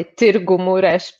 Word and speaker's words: Târgu Mureș: Well, it wasn't that my last result, the one you mentioned in the Romanian, it's actually Târgu Mureș, but okay, it involves Romanian pronunciation.